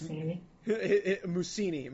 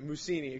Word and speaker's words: Mussini: I know it's spelled differently, but his name's Mussini. – Mussini, excuse me. I misread that, so I'm sorry for all you.